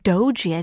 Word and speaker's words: Doge: So if you type in dog, D-O-G-E, me. – It's actually pronounced doji, I didn't know. – Oh. – Being corrected, yeah. – Well, I D-O-G-E, doji.